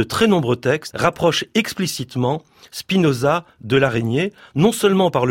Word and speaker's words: Spinoza: que l'on prononce Spinoza en allemand et en yiddish, en tout cas, c'est comme cela que mes maîtres yiddishisants le prononçaient.